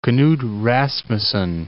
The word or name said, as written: Knud Rasmussen Land